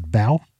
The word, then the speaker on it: Bao: I think it's pronounced Bao, B-A-O,